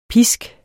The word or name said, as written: pisk